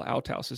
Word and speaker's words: Althaus: And I had asked for the pronunciation, Paul Althaus, Althaus, right? – Yeah, Althaus. – Mm-hmm. Um, is and your dissertation was on Paul Althaus.